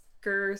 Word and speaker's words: Gir: And it's Gurr spelled like Gurr on... – On Invader Zim. G-I-R.